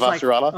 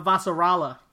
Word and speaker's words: Avasarala: Her name is Avasarala. – Like Avasarala. Thank you. There's a lot of syllables in that word.